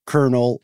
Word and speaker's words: colonel: It's funny that you are mentioning, or it's funny that we're going to talk about pronunciation, because colonel is pronounced like a popcorn kernel, but it's spelled in a very...